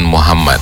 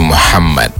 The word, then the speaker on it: Muhammad: Sama kayak Muhammad, bukan Muhammad, tapi Muhammad. – Muhammad. – Nah begitu. – Ada sengaunya yang ditarik ke dalam.